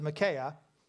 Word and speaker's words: Micaiah: Well, they have all these lying prophets around them, and they bring in one guy whose name we're going to pronounce as Micaiah.